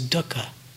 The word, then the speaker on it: dukkha: In Pali, in the language of the Buddha's teachings, the word that's translated as suffering is dukkha. It's spelled D-U-K-K-H-A, dukkha.